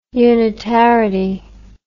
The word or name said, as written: unitarity